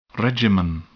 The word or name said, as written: regimen